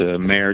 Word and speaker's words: Meir: It's Mayor, just like the horse.